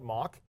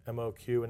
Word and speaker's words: MOQ: And you can ask 100 people on the street how to pronounce it, and you'll get 100 different answers. So I usually just call it mock.